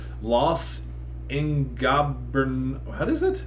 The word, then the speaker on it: Los Ingobernables: Los Ingobernables.